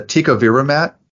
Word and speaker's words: Tecovirimat: Okay, we've talked about the, forgive me if I mispronounce it, the Tecovirimat vaccine. – That's are There, uh, are, uh, yes, got it right.